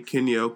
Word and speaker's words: Kinyo: Search for me, Kinyo, K-I-N-Y-O, and, I mean, of course you know my name.